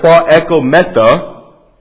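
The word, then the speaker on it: proekometa (προεχόμεθα): That Greek word right there, proekometa, alright, that's how you pronounce that, literally means to jut out or to excel.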